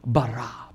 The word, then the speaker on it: bara: When you look at the word that is used, the Hebrew word for create used here is the word bara, B-A-R-A, bara. And you have to say it like that because it's a powerful word.